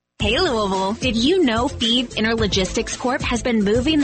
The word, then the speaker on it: Fives: Feve is spelled F-I-V-E-S, like the number, but pronounced Feve.